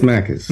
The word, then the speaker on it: Makis: It's Makis.